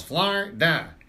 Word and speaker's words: Florida: Two syllables, Florida.